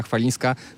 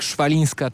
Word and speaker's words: Chwalińska: No, wszystko wskazuje na to, że Francuzi będą musieli nauczać się wymawiać tak jak trzeba nazwisko Polki, bo na razie wychodzi im to raczej jak Szwalińska czy Łalińska, więc nazwiska Chwalińskiej będą musieli się nauczyć.